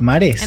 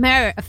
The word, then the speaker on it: Mare: Mares. En realidad en inglés se llama Mare Fish Town, pero es Mare.